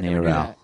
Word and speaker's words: NARAL: Oh, they actually pronounce it NARAL? – NARAL.